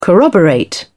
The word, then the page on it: corroborate: British English